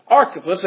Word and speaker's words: Archippus: He says, and say to this guy right here, his name is Archippus, according to the way the pronunciation guide goes, there's an underline under the CH, that would make that a K, right? – Right.